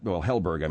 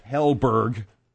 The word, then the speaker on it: Helberg: Simon Hedberg, Helberg, I mean, and... – yeah, he's still friends with him. You got his name wrong. – Helberg.